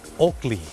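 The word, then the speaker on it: Oakley: คนไทยออกเสียงว่าโอเกลแต่เจ้าของภาษาไม่ได้ออกเสียงว่าโอเกลครับเขาออกเสียงว่าโอกลี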